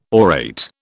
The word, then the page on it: orate: British English